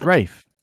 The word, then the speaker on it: Ralph: It's Rafe. It's pronounced Rafe.